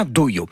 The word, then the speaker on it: dojo: Alors oui, on dit dojo, dojo, on prononce dojo, on dirait un peu un gars qui dit dojo mais qui a rendez-vous chez l'orthophoniste, mais c'est bien dojo.